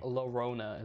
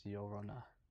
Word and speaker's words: Llorona: See, I've heard of it, but I always pronounce it Llorona. – Yeah, but it. – So that's why. – Llorona is what people. Them white people say. It's Llorona.